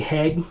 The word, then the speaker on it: Hegg: AJ Hegg It's I got the pronunciation, so Heg, like an egg with an H.